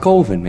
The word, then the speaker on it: Coven: It's pronounced Coven, man. What else could it be pronounced? – Coven, that's the proper pronunciation. – No, Coven sounds like oven, man, and that's just, it doesn't work.